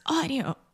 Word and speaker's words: audio: We used to, and then you were like, it's audio. – Yeah. – And now we say it correctly. – So imagine this A-U combination in English. Most of the time, these two sounds combine together and they produce the aw sound.